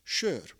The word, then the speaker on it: sör: Because what I didn't know at the time is that there is a word in Hungarian that is pronounced "sör."